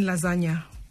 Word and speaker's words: lasagna: Which letter is silent in lasagna? – G. – Yeah, you're right.